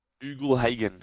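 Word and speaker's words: Ugle-Hagan: Ughlhagen.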